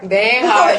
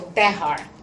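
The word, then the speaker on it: Bejar: Behar, very good. My last name is Spanish, and the J is pronounced like an H, so Behar.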